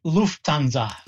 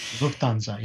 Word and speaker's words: Lufthansa: How do you say that Air Canada and Lufthansa? Every time I see it in writing, I struggle with the word. – Lufthansa. – Lufthansa. – Lufthansa, yes. The German version of the German airline, Lufthansa.